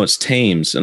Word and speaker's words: Thames: And then my father said, no, it's Thames. And I was like, oh, Thames. Yeah. Thames. – It's Thames. – Yeah. And then he's like, well, no, that's how it's said.